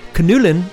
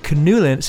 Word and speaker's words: Knülle: You pronounce the N. Knüllen. – Yeah, we pronounce the N in German. The K-N in right. German. – Knüllen. See, in English, the K is silent.